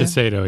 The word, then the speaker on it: Shiseido: And now the company Shiseido, I think that's how you say it from Japan. – Shiseido, yeah.